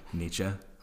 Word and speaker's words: Nietzsche: Her dead mentors, Kierkegaard and Nietzsche. Am I saying it right? – Nietzsche.